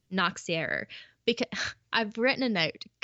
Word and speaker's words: Nachtzehrer: I've written a note that it sounds like Nazi error, but with a k. – Okay. – Nachtzehrer.